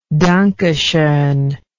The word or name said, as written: danke schon